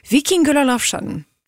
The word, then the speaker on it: Víkingur Ólafsson: He's Icelandic, so it's really fun to pronounce his name in an Icelandic accent, as best as I can, at least. Víkingur Ólafsson.